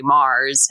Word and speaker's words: Mars: Yeah, well, my name is spelled a little differently. So I pretty much, if you search my name on any internet surfer machine, you'll find me. So it's Rebekka, R-E-B-E-K-K-A, Mars, M-A-R-S, like the planet.